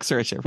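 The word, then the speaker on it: Saoirse: It's like Sersha, rhymes with inertia.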